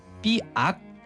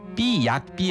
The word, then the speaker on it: 삐악: 근데 이게 우리가 보통 쓸 때 삐약 삐약이라고 쓰잖아요. – 발음도 그렇게 하는데요. – 근데 정답은요. 삐악이 맞아요. – 왜? 왜? – 삐악. 또 화났다. 화났다. 삐악 삐악이라고 쓰고 발음할 때도 삐악 이라고 해야 돼요.